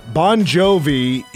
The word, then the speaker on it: Bongiovi: Bon Jovi is spelled, his real last name, Bon Jovi, it might be a different pronunciation, but it's spelled B-O-N-G-I-O-V-I. – Ooh, weird. – Yeah, made the right call with going with B-O-N space G-O-V-Y.